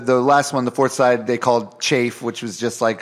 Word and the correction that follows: chaff: Chaff. Is what the shit they throw out of. – It is pronounced chaff, right? – Oh, chaff.